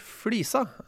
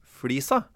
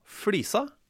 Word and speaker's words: flisa: Så det noen som allerede nå sitter og irriterer seg over at jeg sier flisa, mener at det skal være flisa. Mm. Det det ikke. Det uttales altså flisa.